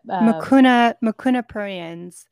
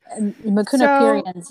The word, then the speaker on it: Mucuna pruriens: How do you pronounce it again? – Mucuna pruriens. – Mucuna pruriens.